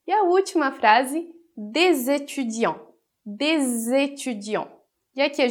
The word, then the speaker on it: des étudiants: Então, a gente vai fazer a liaison produzindo o som de Z entre D e étudiant. Então, des étudiants, des étudiants, que é uns estudantes.